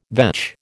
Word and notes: vetch: American English

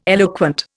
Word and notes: eloquent: Indian

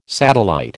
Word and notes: satellite: American English

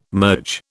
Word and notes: merge: British English

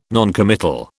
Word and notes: noncommittal: British English

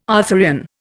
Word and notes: Arthurian: Indian

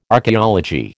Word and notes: archeology: American English

